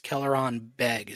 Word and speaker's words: Kelleran Beq: I think it's Kelleran Beg. Is that correct? – Yeah.